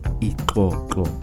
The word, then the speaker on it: ikoko: Basically, what I'm trying to say is we pronounce XOXO as Ikoko. Try pronouncing that and tell me if you can actually produce that sound. It's pretty much hard for those without the click sound, you know.